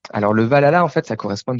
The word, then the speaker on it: Valhalla: Si, si, c'est Valhalla, ouais, très bien.